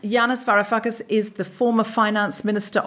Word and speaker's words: Yanis Varoufakis: And we've just watched an episode of an amazing new documentary series, In the Eye of the Storm, The Political Odyssey of Yanis Varoufakis.